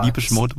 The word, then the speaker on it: Depeche Mode: Guck mal, du sprichst auch richtig aus, ne? Ich schreibe Depeche Mode, Depeche Mode, Depeche Mode. – Habe ich richtig gesagt, oder? – Du hast richtig gesagt.